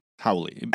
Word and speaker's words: haole: So H-A-O-L-I-E. Yeah. – No, okay, H-A-O-L-E, howley.